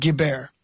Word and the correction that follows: Guilbert: Yeah, Rudy's mom. – Close. Guilbert. Actress.